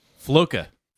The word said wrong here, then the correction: Floki: Floki. – Floki. Like, yeah, Floki.